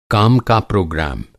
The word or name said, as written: प्रोग्राम